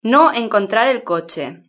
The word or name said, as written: no encontrar el coche